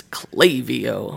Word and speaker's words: Klaviyo: I go, okay, tell me once and for all, how do you pronounce it? And it's Klaviyo.